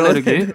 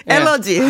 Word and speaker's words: 알레르기: 예, 알레르기. 엘러지. 네. 예. 아, 예. – 아, 미국 발음 엘러지. 우리 발음 알레르기.